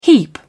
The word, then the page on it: heap: British English